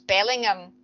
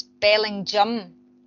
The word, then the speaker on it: Bellingham: The last main checkpoint on, you know, on paper, he'd think it was Bellingham, and it's not, it's Bellingham. That's how the locals pronounce it, and they will correct you if you pronounce it wrong.